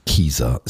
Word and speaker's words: Kezar: also offiziell spricht man es aus Kieser Stadium.